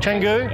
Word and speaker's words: Canggu: Canggu? – Yeah.